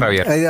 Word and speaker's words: Ravier: A Raver. – Adrián también, Ravier. – Adrián Ravier, lo dije bien.